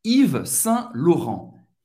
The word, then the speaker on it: Yves Saint Laurent: L-A-U tem o som do lo. A-U tem o som do o. Laurent. Et N tem o som do en. Et non prononcia O-T no final. Vamos juntos, Yves Saint-Laurent.